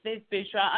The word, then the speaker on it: Viv Boucherat: It's Viv Boucherat.